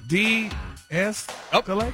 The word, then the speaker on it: de-escalate: You got to say it right first. Look at the pronunciation, the breakdown. – De-escalate.